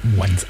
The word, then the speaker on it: Mwanza: Mwanza heißt das. Richtig, MW. – MW, Mwanza. Aber aussprechen, ja, okay.